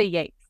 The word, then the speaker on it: Yeats: I did say Yeats.